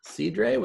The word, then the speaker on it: cidre: Cidre with the. – I'm telling you, that's how they pronounce it.